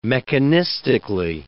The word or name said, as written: mechanistically